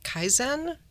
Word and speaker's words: Kaizen: We don't have a lot of time left, but I did want to ask this one question about something called Kaizen. Is that how you pronounce it? – Yes.